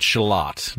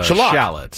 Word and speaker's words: shallot: He pronounces it shallot, not a shallot. Shallot. – Okay. – In this country, we say shallot. – Shallot. – But he says it's a shallot.